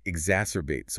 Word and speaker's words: exacerbate: The text says that competition can exacerbate social tensions. Exacerbate. – The pronunciation of this word can often trip up learners. It's pronounced X-A-S-S, or bait. Exacerbate.